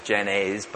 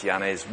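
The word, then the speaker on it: Janszoon: If you're not a Dutch person, you'll probably pronounce that as Janez, but it's Janez.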